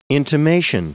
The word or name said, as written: intimation